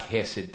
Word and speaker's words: hesed: H-E-S-E-D. Now, I didn't pronounce it right. The Jews and the people who speak Hebrew, that H sound is a real guttural, something like that. I can't make it. So I'm sure I did not say it right. It looks like hesed to me.